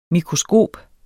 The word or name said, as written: mikroskop